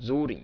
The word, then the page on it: Zurin: Zuuring